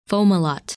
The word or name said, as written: Fomalhaut